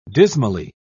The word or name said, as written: dismally